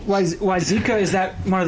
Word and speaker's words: Wayzata: Is it Wayzata? – Wayzata.